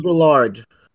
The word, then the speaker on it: Baudrillard: Jean Baldr. – Baudrillard.